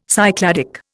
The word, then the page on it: Cycladic: Indian